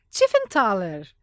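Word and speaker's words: Tiefenthaler: E a pessoa na frente deste projeto é o Paulo Tiffenthaler. Não é isso? É esse seu nome, não é? Tiffany é, Thaler? Você falou com a pronúncia correta.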